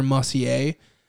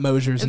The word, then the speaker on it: Mosier: And also, you know how they keep talking about Dr. Mossier? – Yeah. – That's a mispronunciation of Scott Mosier's name.